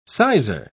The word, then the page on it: sizer: British English